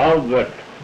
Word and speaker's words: Albert: Albert. You want me to spell it? A-L-B-E-R-T. And Wilhelm, W-I-L-H-E-L-M, Wilhelm. And Trana, T-R-A-N-A.